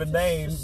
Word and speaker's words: inane: I'm having trouble giving you a definition of inane because the word isn't there for me right now. – Inane rhymes with insane.